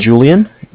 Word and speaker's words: Julian: My name is Julian. It's J-U-L-I-A-N. – Okay. – Calling with Bank of Cardiff. Cardiff is spelled C-A-R.